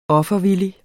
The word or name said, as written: offervillig